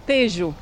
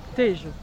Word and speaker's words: Tejo: So how should we pronounce that again? – Tejo. – Tejo.